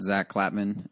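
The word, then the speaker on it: Zack Klapman: Follow me on Twitter at Zach Klapman.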